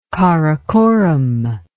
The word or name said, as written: Karakoram